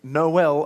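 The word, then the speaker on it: Noël: But then we go on to say Noel a bunch more times, don't we? Noel, Noel, Noel, Noel. Born is the King of Israel. And it is helpful that it rhymes, but why no L? Why not no P?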